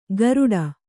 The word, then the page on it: ಗಾರುಡ: gāruḍa